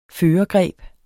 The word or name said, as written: førergreb